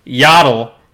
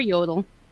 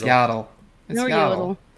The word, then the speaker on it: Yaddle: We're getting Yaddle in Lego Star Wars. – Or Yodel. – It's Yaddle. There's no O in their name.